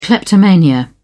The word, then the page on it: kleptomania: British English